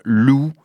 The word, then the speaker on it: Leu: Mr. Lou, ça s'écrit L-E-U and the Hackies.